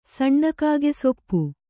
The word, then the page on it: ಸಣ್ಣಕಾಗೆಸೊಪ್ಪು: saṇṇa kāge soppu